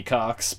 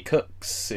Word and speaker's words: Koch: As far as corrections, I guess that in last episode we were calling old Jimmy Cox Jimmy Cox because it's spelled K-O-C-H. – Yeah. – But this looks fucking right. Asshat pronounces it Jimmy Cooks.